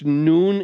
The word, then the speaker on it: nun: And so I know yours, you know, it's N-U-N. Some people say it's none. It's not. It's a capital U. It's a long U. It's a hard U. So it's noon is how the word is it.